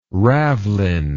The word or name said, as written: ravelin